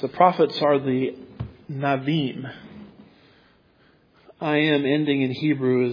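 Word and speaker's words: Nevi'im: So Nabim is really the way it's pronounced as far as I understand.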